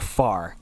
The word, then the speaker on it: Fahr: It's pronounced Far. And that one, as a dyslexic child myself, took a long time to get around. But it's pronounced Far, and it's weird spelling. – It's spelled F-A-H-R.